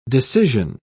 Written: decision

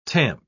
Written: tamp

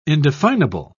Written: indefinable

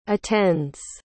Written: attends

